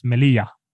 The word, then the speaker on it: Melilla: Eh, tar vi nummer fem, eh, Melilla. – Det får vara en spansk ort. – Det är helt riktigt. Ante att uttalas Melia.